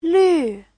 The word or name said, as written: lv4